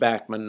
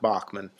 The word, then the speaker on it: Bachman: And yes, it is pronounced Bachman, not Bachman, much to my chagrin. But hey, Randy can pronounce his name any way he wants, speaking of Guitar Heroes.